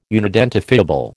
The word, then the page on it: unidentifiable: American English